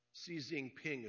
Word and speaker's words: Xi Jinping: The Chinese president, who has been called a dictator by our president, which I think he said it right, Xi Jinping is the pronunciation of his name.